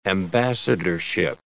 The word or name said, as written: ambassadorship